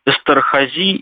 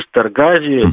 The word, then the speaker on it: Эстерхази: Или Эстерхази, как правильно? – Эстерхази или Эстергази, зависит от ударения.